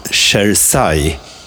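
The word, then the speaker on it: Cersaie: Leif, här får du rätta mig om jag uttalar det fel. Keramikmässan Chersai i Bologna. E corretto, e corretto.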